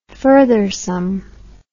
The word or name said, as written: furthersome